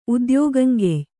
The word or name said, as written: ಉದ್ಯೋಗಂಗೆಯ್